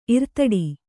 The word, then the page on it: ಇರ್ತಡಿ: irtaḍi